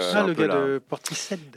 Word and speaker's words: Portishead: C'est Portishead. – Ok. – Portishead.